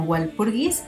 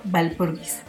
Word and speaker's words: Walpurgis: Al menos como nosotros lo leemos, casi siempre ha sido conocida como Walpurgis, eh, porque empieza con W, pero sí, en este, en este episodio vamos a estarle llamando Valpurgis en su pronunciación correcta.